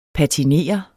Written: patinere

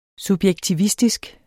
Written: subjektivistisk

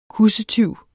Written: kussetyv

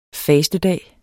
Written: fastedag

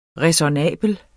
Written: ræsonnabel

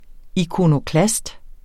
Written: ikonoklast

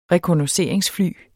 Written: rekognosceringsfly

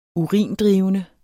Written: urindrivende

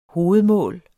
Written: hovedmål

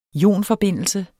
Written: ionforbindelse

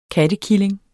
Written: kattekilling

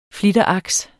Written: flitteraks

